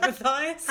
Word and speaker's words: Matthias: Relight, bit of a random one, my surname rhymes with my fire, so the Take That song, Relight My Fire, is a bit like Relight Matthias.